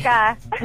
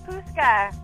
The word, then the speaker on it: Pustka: It's Pustka. – What is it? – It's Pustka. – Pustka. – Pustka. Oh, yes. It's a silent T.